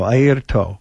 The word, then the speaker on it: Airto: Points to his eye, his ear, and his toe, Airto Moreira.